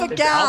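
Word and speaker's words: Alpha-gal: Marjorie, I heard you pronounce Alpha Gale on the radio this morning, and I decided I had to call in and teach you how to pronounce it. – Oh, please. Go right ahead. – Alpha Gal. – Oh, Gal? Alpha Gal.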